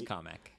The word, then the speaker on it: Kamek: They have Kamek. Apparently, is how you pronounce this name. Unless yeah, Kamek. – They gave the announcer no oversight. – It's Kamek.